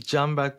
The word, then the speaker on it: Canberk: So coming to that only, when we talk about B2B marketing, first of all, just wanted to know that it's Canberk or is it Canberk? Because I might be pronouncing your name wrong. – It is Canberk, but I don't mind.